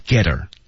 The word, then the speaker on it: her: 然后呢，我们注意这句话 get her 那个 t 和 her 之间是有连读的，her 第一个字母 h 不发音，然后 get her 连起来，这个特别常见这种。